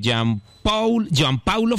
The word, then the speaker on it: Jean Paulo: Bueno, es un arquero de 30 años, juega en el América de Cali, Jean Paulo Fernández. – Jean Paul Fernández. – Bueno, se escribe Jean Paulo, se lee Jean Paul.